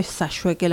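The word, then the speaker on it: Sayhueque: relación a mi identidad, porque muchos que saben dicen que es Sayueque. En mi familia siempre fue Sayueque la pronunciación, entonces como que me cuesta nombrarlo de otra manera.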